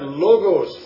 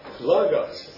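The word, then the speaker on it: Logos (λόγος): It is not Logos, folks, because it's spelled Lambda Omicron, and the Omicron has the sound of Ah, Logos, Logos.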